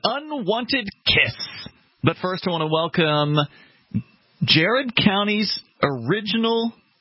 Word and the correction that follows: Garrett: hometown station, or Garrett County, excuse me. Garrett is the proper pronunciation.